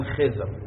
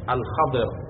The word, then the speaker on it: خضر: پیامبر موسی سه تا کار ظاهری از خضر علیه السلام دید، مثلا در زمان فارسی میگن خضر، اصل تلفظ الخضر،